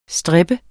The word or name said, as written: stripper